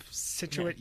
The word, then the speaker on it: Scituate: You just ignore the C sort of Scituate. Yeah. You sit with it with Scituate.